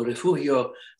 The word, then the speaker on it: Refugio: It's a Spanish word, and the Texans decided that we were going to pronounce it Refugio. Refugio is a small town on the Gulf Coast between Corpus Christi and Victoria. And the actual word is refugio, which means refuge, which it was when Spain was owned the territory of Texas.